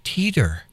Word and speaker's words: Teeter: By Adam Teeter. Teeter rhymes with skeeter.